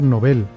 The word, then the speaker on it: Nobel: que se debe pronunciar novel, porque no lleva tilde y no termina en N, S o vocal, así que, según las reglas ortográficas de la lengua española, debe ser una palabra aguda.